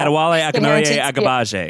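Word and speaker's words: Adewale Akinnuoye-Agbaje: Yeah, Adewale Akinnoye Agbaje is how you pronounce it.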